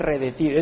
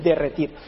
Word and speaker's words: derretir: Y cuando empezaba el verano, la nieve en Colosas, en las montañas de Colosas, empezaba a, dijo uno, redetir, pero no se dice redetir, es derretir.